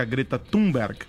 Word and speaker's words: Greta Thunberg: O porta-voz da presidência, Otávio Dorrego Barros, disse ontem que Jair Bolsonaro não foi descortês ao chamar a ativista sueca Greta Thunberg. A pronúncia é essa mesmo? – Isso.